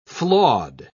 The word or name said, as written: flawed